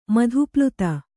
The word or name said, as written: ಮಧುಪ್ಲುತ